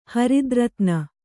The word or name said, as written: ಹರಿದ್ರತ್ನ